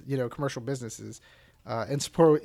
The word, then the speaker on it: Sapporo: Sapporo? – Sapporo, yeah.